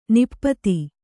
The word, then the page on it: ನಿಪತ್ತಿ: Kannada